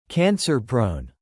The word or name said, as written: cancer-prone